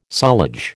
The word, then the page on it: sullage: American English